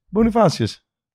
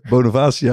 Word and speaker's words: Bonifacius: Bonifacius. Ja, die ken je toch? – Ja, maar ik wist niet hoe ik hem zou uitspreken. Ik wou zeggen, Bonavacia, maar dat is die voetballer toch?